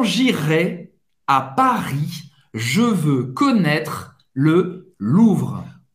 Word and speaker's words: connaître: Olha, o E no final não é pronunciado. Connaître.